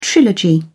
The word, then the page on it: trilogy: British English